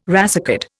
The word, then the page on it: racecard: Indian